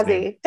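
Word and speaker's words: Suozzi: But the Santos-Suzy race, and I apologize if I'm mispronouncing that gentleman's Swazi. – Name. Swazi. Okay, thank you. Swazi.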